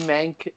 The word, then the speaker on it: Menk: Yeah, it's at D Menk, M-E-N-K, 33, on Twitter.